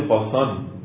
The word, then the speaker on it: قاسان: علتی هم که بعضی کلمات کاف در عربی که میشه قاف میشه، کاشان در عربی قاسان تلفظ میشه، که هایی که در فارسی تلفظ میکنن عربا قاف میشنیدن.